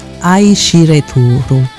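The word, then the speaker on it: Aishireturu (愛してる): Ti amo in giapponese si pronuncia Aishireturu.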